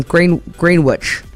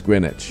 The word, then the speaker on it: Greenwich: Well, no, because I keep calling things Greenwich. – And it's Greenwich.